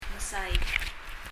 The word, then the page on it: mesaik: [mɛsaik]